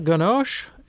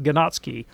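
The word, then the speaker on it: Ganoczy: Yes, Alexandre Ganoche. And that's how you pronounce it, but it doesn't look anything like that. It's G-A-N-O-C-Z-Y, I think. It looks like Ganotsky, but it's pronounced Ganoche.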